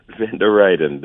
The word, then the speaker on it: Van der Reijden: Van der Reijden.